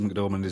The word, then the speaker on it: McDormand: Frances McDormand. Is that right? Is that how you say it? – Frances McDormand is yep.